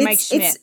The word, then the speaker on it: Mike Schmitz: father mike smith and he, it's, Mike Schmitz. It's, yeah, yeah, Mike Schmitz.